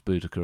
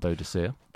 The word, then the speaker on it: Boudicca: So, what you were, I mean, we learned how to pronounce Boudicca, of course, not Boadicea. – That was my favourite bit, though, because the way it transpires that there's two ways of pronouncing the name and two very different functions that those names serve.